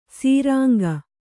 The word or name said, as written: ಸುರಂಗ